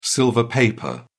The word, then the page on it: silver paper: British English